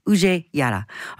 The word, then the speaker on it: Uje Jara: Now, at this point in time, I would like to officially and formally apologize to Uje, whose name I've mispronounced for many years. I'm so sorry, but I got it right now. Uje Jara.